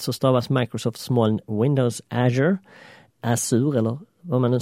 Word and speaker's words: Azure: Och vanligtvis kommer jag nog att välja att uttala Azure på engelska,